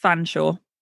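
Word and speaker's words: Featherstonehaugh: probably the most impressively poshed is something that is spelt Featherstone Hoare. So Featherstone, H-A-U-G-H, all one word, and pronounced Fanshawe.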